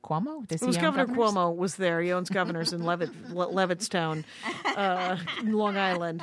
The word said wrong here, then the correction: Levittown: I'm probably saying it wrong because it's probably Levittsville. – No, it's Levittown. – Oh, is it Levittown?